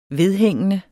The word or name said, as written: vedhængende